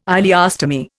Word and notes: ileostomy: Indian

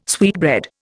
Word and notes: sweetbread: Indian

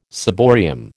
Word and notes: ciborium: American English

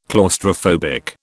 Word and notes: claustrophobic: British English

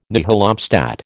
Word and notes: nihil obstat: American English

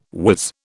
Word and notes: whiz: British English

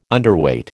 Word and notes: Underweight: American English

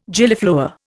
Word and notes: Gillyflower: Indian

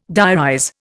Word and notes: diarize: Indian

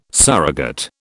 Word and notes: surrogate: British English

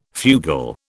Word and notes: fugal: British English

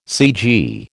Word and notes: cg: American English

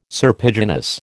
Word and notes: serpiginous: American English

